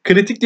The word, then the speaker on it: Ciritik: Kritik diye okunuyor ama Ciritik K ile bitiyor. C ile başlayan bir e, büfenin sahibi.